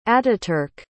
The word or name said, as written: atatürk